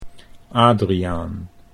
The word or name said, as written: Adriaan